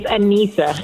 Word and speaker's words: Anisa: It's Anisa.